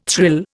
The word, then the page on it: thrill: Indian